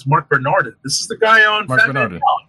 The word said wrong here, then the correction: Bernardin: No, Mark Bernardine.